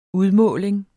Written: udmåling

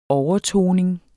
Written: overtoning

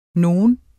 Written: nogen

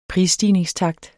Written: prisstigningstakt

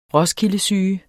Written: roskildesyge

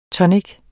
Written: tonic

